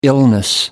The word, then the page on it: illness: American English